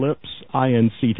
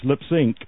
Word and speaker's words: Lipps Inc.: Now, Funky Town is by Lips, Inc., but you run it together. The way it's supposed to be pronounced, you run Lips, I-N-C, together so that it's Lips, Inc., which, of course, would be like moving your lips to music. Lips, Inc., but it's spelled L-I-P-P-S-I-N-C.